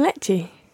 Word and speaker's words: leche: Un café con leche.